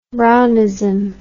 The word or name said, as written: Brownism